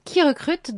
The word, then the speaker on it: KeyRecrut: Alors, Qui Recrute, donc ça s'écrit comme une clé en anglais, K-E-Y-R-E-C-R-U-T, est un cabinet de recrutement à vrai qui a été créé en 2016.